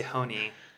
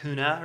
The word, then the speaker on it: Hoehne: Well, I've been told that I say it incorrectly by Germans. It's a German heritage name. I say Hoehne, and they say that there's no way that that's how you'd say it. I say like Hoehne or something.